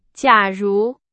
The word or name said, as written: 假如